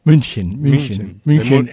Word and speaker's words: München: München, en castellano, Múnich.